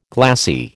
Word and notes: glassy: American English

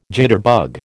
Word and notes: jitterbug: American English

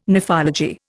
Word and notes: nephology: Indian

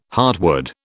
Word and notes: heartwood: British English

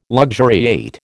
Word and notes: luxuriate: American English

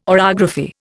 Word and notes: orography: Indian

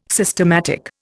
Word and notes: systematic: Indian

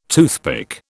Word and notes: toothpick: British English

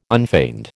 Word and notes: unfeigned: American English

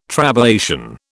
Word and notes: trabeation: British English

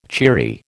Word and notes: cheery: American English

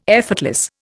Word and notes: effortless: Indian

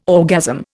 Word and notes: orgasm: Indian

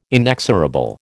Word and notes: inexorable: American English

